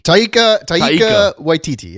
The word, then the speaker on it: Taika Waititi: Did I say it right? Taiki? – Taika Waititi, yes.